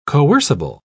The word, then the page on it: coercible: British English